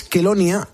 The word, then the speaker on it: Chelonia: Os llamáis Kelonia, se escribe Chelonia, por si algún ponedor que lo está escuchando quiere investigar todo esto.